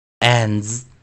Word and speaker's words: ends: 那 d s 结合会念 z z z，先念的，再念 z。 z z z，这个音比较难发音哦，再注意听一下。 z z z，ends ends ends，注意啊，我们是念先念 an，再念 z ends ends ends，注意哦，ends ends ends。